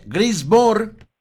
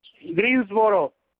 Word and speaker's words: Greensboro: Estamos hablando de acá de Norte Carolina, de Greensboro. – A ver, enséñame a pronunciar ese nombre. Greensboro. – Greensboro. – Ah, Greensboro. – Greensboro, Norte Carolina.